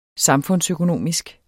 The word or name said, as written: samfundsøkonomisk